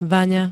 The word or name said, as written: VAŇA